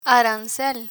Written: arancel

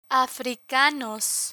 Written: africanos

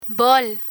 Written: bol

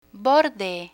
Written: borde

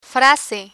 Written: frase

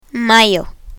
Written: mayo

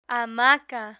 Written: hamaca

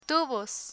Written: tubos

